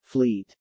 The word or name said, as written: Fleet